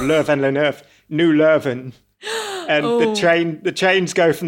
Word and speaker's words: Leuven: Yes, it's very confusing because it depends on what language you're speaking. Leuven is... – Leuven.